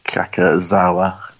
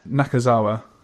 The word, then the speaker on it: Nakazawa: And by Keiji Kakazawa. That's my pronunciation. – Yeah, Nakazawa, I think it might be.